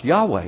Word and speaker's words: Yahweh: That's why when a Jew reads the four letters, the tetragrammaton, Y-H-V or W-H, he will never pronounce those words, those letters. Those letters are pronounced Yahweh.